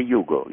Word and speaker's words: Hugo: The boy in the book is it pronounced Hugo? – Hugo. – In English. – Hugo. – In English. – In English, you will say Hugo. Yes.